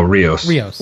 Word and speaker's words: Rios: You know, in this episode, what is it? Rosio? Who's the captain? – Oh, Rios. – Rios. – I always say his name wrong.